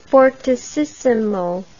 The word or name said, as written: fortississimo